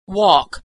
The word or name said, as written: walk